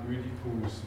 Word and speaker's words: Ödipus: dass man das OI ein bisschen in Richtung Ö ausgesprochen hat, wie auch immer, ähm, Ödipus.